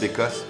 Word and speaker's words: Bécosse: But that's how we would pronounce it, Bécosse, Bécosse, Bécosse.